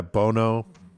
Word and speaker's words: Bono: Bono? – Yeah, Bono.